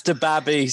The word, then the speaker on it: DaBaby: Now, quote me if I'm wrong or right, but is his name DaBaby? – It's DaBaby. – DaBaby. – Sorry, I'm saying it wrong. It's DaBaby. It is DaBaby.